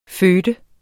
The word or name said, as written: føde